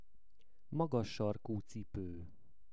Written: magas sarku cipo

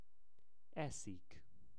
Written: Eszik

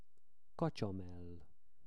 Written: kacsamell

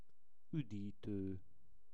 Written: udito

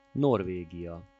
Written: Norvegia

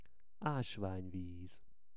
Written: asvanyviz

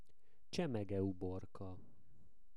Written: csemegeuborka

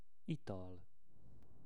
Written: ital

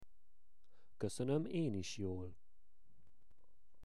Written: koszonom en is jol

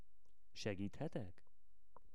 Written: segithetek

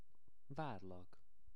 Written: varlak

